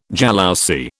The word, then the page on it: jalousie: British English